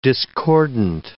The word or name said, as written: discordant